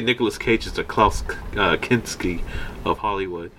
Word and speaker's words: Kinski: Yeah, Klaus Kinski.